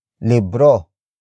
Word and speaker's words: libro: And that main noun is libro. Libro. Libro. Libro is spelled as L-I-B-R-O.